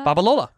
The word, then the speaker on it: Babalola: Babalola.